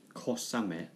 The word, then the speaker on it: Kosame: I think fine rain would be kosame. – Kosame. – Kosame. So ko is the Japanese kanji for small. – Yeah. – And ame, same, is also ame. So kosame.